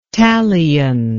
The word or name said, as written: talion